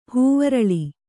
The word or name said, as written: ಹೂವರಳಿ